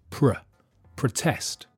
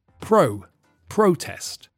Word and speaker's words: protest: The stress shifts, and so in the verb, the first vowel sound is weak, pr, protest. And in the noun, the stress is on the first syllable, so the vowel sound is strong, pro, protest.